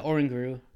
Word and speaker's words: Oranguru: Oranguru. Is that how you say it? – Yeah, Oranguru.